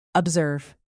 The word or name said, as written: observe